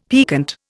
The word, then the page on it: piquant: Indian